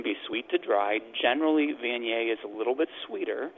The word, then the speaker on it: Viognier: Viognier. That's how I remember how to say it. I actually have to spell out yay at the end, so Viognier. So Viognier, yes.